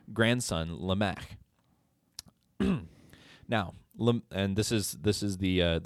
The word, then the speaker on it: Lamech: Well, down through the line of Cain, earlier in verses, those verses where it goes through his Cain, we get this character, Lamech. I think I'm saying that right. – Lamech. – Lamech. Yep. I don't know.